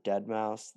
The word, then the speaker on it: Deadmau5: And so one of the things I did today is I was listening to, I think you pronounce it Deadmau5, the EDM techno guy.